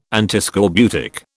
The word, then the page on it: Antiscorbutic: British English